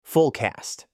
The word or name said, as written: full cast